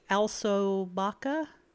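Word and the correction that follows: Alcobaça: Alcobasa. It's all C's in there, but one of the C's has a whishy on it, and so it's pronounced with an S.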